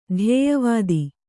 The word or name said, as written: ಧ್ಯೇಯವಾದ